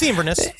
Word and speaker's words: Inverness: I'm sure it's pronounced Inverness or Inverness. – It's Inverness.